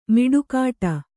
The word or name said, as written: ಮಡಿಕಟ್ಟು